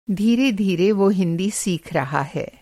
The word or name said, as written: धीरे-धीरे